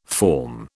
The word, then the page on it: forme: British English